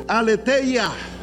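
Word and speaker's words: Aleteia (ἀλήθεια): Y hay otra palabra que quiero que usted pronuncie en griego, y con esta termino: Aleteia. Aleteia. Diga conmigo: Aleteia.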